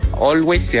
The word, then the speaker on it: Always: Quería pedir la canción de Bon Jovi o Always, así se pronuncia. – Así mismo. – Eso, muchas gracias. – Oye, pronunciaste perfecto, no que no te dé vergüenza.